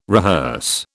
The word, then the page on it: rehearse: British English